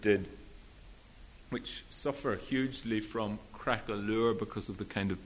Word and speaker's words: craquelure: It's about a very ugly word, this poem, crackalure, which is spelt, I'm sure you all know the word, C-R-A-Q-U-E-L-U-R-E. It's the word applied to paint when it cracks on an old painting long after it's dried. It's called crackalure.